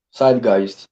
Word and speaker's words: Zeitgeist: Zeitgeist, con Z. Zeitgeist, pero se escribe Zeitgeist. Es una, es una palabra alemana.